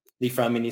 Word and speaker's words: Li-Fraumeni: So if you search LFS syndrome, Li-Fraumeni syndrome, or just LFS mutation, you'll find it.